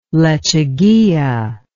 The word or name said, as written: lechuguilla